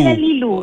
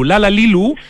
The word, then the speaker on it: Lala Lelu: Lala Lilu. – Sí, pero se escribe Lala Lelu, Lala Lilu.